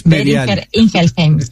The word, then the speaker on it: Boehringer Ingelheim: Esta iniciativa es apoyada por el Banco Seguro del Estado, el Banco Itaú y Boehringer Ingelheim, que nunca me sale bien cómo se pronuncia. – Boehringer Ingelheim, exactamente.